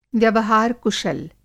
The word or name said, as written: व्यवहार-कुशल